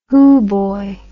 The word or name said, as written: hoo boy